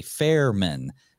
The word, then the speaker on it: Fehrmann: I was more concerned about pronouncing your last name correctly, Fehrmann. – Correct. I'm a fair man.